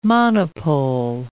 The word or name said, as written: monopole